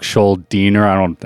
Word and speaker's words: Schuldiner: There's Chuck Schuldiner. I think that's how you pronounce his last name. – That is how you pronounce it.